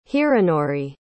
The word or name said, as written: hironori